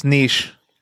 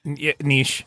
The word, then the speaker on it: Nietzsche: Pretty sure it's pronounced niche. – Nietzsche.